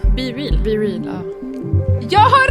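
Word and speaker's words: BeReal: Det här är det sjuka, att i App Store, då står det så här, det uttalas Be Real.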